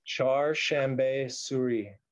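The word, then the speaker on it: Chaharshanbe Suri: I mean, here's people jumping over the fire for the uh, how do you pronounce this holiday again? Char Shambe Suri.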